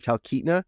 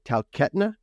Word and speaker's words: Talkeetna: And also the town of Talkeetna, they said Talkeetna, and it was like, whoa, this was filmed in Canada for sure.